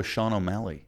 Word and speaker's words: Sean O'Malley: You remember our boy Sean O'Malley? – Mm.